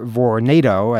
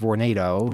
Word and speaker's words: Vornado: I understand it's pronounced Vornado. – Vornado, Vornado. – Well, I've always been saying Vornado. At the meeting, the people from the company were saying Vornado. I figure they probably know how to pronounce their name.